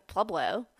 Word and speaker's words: Pueblo: You say it very white, but sure. – How do I not say it white? Tell me how to say it. – My parents live in Pueblo. – Pueblo. – Yeah. – Okay. – It's okay. Everybody does, so it's fine.